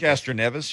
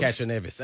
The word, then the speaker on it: Castroneves: Casanovas. Is that his name? That's how you pronounce it? – Castroneves. You were close. – Castroneves. Okay, that was close.